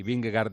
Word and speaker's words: Vingegaard: Por cierto, para situar a los oyentes que oirán el mismo ciclista de dos maneras dicho, Vingegaard dice Eri y Vingegaard decimos el resto de los mortales, tiene razón Eri en cómo lo pronuncia, porque así se pronuncia en danés, y si no, escuchen a esta aficionada que danesa que nos lo va a confirmar.